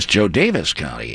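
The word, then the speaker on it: Jo Daviess: You know, another one that was called to my attention recently is I always called it Joe Davies County, but it's I guess Joe Davis County is the correct pronunciation.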